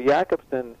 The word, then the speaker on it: Jakobson: He was a Czechoslovakian structuralist, Jakobson, or Jacobson, but I think they pronounce the soft J, so it's Roman Jakobson. So Jakobson,